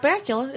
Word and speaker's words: Bakula: And you know the great thing about Scott Bakula is his name rhymes with butt Dracula.